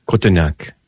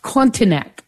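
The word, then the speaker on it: Cotignac: and Father, can you pronounce this, where the Holy Family appeared together? It's Cotonac. – Cotonac. Oh, that's beautiful.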